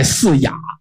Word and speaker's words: 野: 我记得我们上初中的时候语文老师说这个地方应该念笼盖四雅，那个四野应该念成雅，问他为什么，他说我不知道，我们老师教的，但是后来吧也不这么教了，都按普通话，就是敕勒川阴山下，天似穹庐笼盖四野，都这样念了。